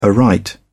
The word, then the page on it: aright: British English